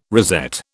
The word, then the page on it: rosette: British English